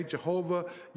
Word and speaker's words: Jehovah: Jehovah, some would say Yahweh, and that's just because of the way it's spelled in the Hebrew.